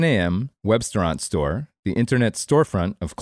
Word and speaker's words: Webstaurant: On a snowy January morning in Lancaster, Pennsylvania, a flat-screen monitor in a former Mennonite elementary school displays the vital signs of Webstaurant Store, spelled like restaurant but with web at the beginning.